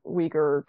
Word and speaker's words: Uyghur: And yeah, actually, he would write about the, I might be about to pronounce this wrong, the Uyghur population. – Uyghurs, yes.